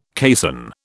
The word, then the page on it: caisson: British English